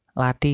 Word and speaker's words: Lathi: lat, lati ini bacanya agak agak ti gitu ya